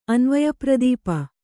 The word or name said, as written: ಅನ್ವಯಪ್ರದೀಪ